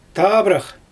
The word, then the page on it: taabrax: Xyzyl